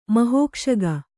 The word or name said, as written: ಮಹೋಕ್ಷಗ